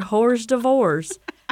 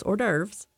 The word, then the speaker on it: hors d'oeuvres: whores divorce? And he came back and he was like, darling, it's usually pronounced hors d'oeuvres on my yacht.